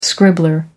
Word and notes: scribbler: American English

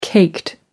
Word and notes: caked: British English

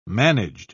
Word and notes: managed: British English

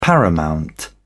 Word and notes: paramount: British English

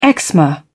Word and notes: eczema: British English

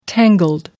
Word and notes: tangled: British English

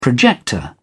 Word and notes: projector: British English

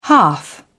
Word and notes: hearth: British English

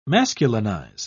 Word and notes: masculinize: British English

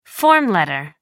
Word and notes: form letter: American English

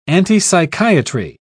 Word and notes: antipsychiatry: British English

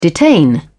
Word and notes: detain: British English

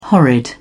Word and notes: horrid: British English

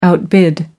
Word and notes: outbid: American English

